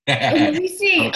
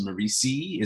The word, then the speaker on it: Merisi: Because it spells like Maurice, but it's actually pronounced Merisi. It's my wife's maiden name. She's Leona Merisi, so very Italian.